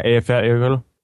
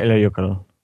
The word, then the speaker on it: Eyjafjallajökull: Eyjafjallajökull. – Come on. Wait. Come on. Do it again. – Eyjafjallajökull. – Oh, wow. – Eyjafjallajökull? – Eyjafjallajökull.